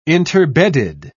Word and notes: interbedded: British English

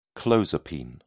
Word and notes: clozapine: British English

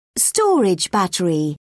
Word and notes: storage battery: British English

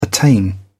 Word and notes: attain: British English

